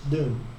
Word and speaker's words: Dune: Dune. ¿Lo dije bien? Sí.